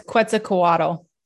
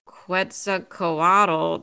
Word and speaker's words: Quetzalcoatl: Am I pronouncing that? That's Quetzalcoatl. I know how to say that one.